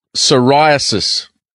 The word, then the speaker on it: psoriasis: How do you spell psoriasis? – I know. It's a tricky one. So it's P-S-O-R-I-A-S-I-S. – Gee, it'd be a good Scrabble score. – Yeah, exactly. Silent P.